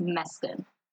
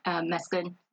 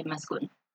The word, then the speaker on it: Meskin: It's pronounced Meskin. – Meskin? – Uh-huh. Meskin. – Meskin or Meskin? – Americans say Meskin, but, you know, in Eritrea, they say Meskin.